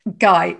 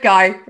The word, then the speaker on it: Guy: I also could be incorrect, and she pronounces it the French way, Viv Guy. So, Viv, how do you pronounce your last name? – Guy. It's plain and simple. Viv Guy.